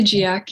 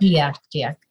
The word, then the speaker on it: GIAC: When we were talking, you know, and preparing for this interview, you mentioned this deliberate structure of the, you say the GIAC, is that how you say it? – I say GIAC, yep. – GIAC.